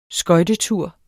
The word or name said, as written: skøjtetur